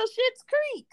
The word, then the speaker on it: Schitt's Creek: Schitt's Creek, even though that was the last name, but yeah. pronunciation is still Schitt's Creek.